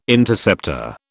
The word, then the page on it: Interceptor: British English